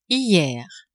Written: hier

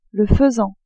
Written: faisan

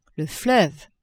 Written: fleuve

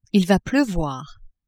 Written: il va pleuvoir

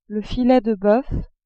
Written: filet de boeuf

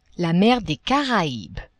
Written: mer des Caraibes

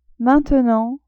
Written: maintenant

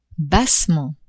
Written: bassement

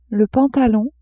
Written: pantalon

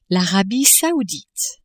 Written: Arabie Saoudite

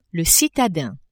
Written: citadin

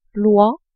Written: oie

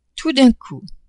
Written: tout d'un coup

